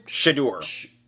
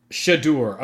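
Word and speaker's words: Shedeur: Shadur. – Shadur.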